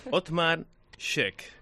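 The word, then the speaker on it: Othmar Schoeck: Ya, ya voy a decir el nombre. Otmar Scheck. – Muy bien, maravilloso. – Sí. Oh, lo he dicho bien.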